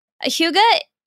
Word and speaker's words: Hygge: So if I may, Hygge is spelled H-Y-G-G-E. I think that's important. As you might see it out into the wild, you'll know exactly how to pronounce it. It is Hygge.